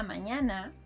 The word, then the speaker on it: mañana: To pronounce it, think of the words onion and canyon. Now, listen and try to repeat after me. Esta mañana vi una araña.